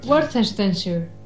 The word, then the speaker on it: Worcestershire: Worcestershire, Inglaterra. Es un sitio muy raro. Bueno. – Worcestershire.